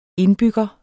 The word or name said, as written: indbygger